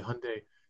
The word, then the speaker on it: Hyundai: To be fair to you, a couple of years ago, or several years ago now, the Hyundai marketing team actually came to the American companies and the dealers specifically and said, we want you to pronounce it like Sunday, but with an H.